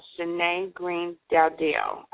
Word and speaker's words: Shani Greene-Dowdell: Shani Greene-Dowdell.